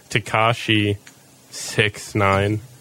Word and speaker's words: Tekashi 6ix9ine: Oh, it tells you how to pronounce it. Tekashi 6ix9ine.